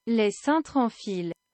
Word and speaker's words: Les Cintres en Fil: So this is it in a better pronunciation of French, even though I should be better than I am. – Les Cintres en Fil.